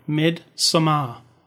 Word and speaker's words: Midsommar: I'm going to say it right from now on. Midsommar. – Because that's AR at the end instead of an ER.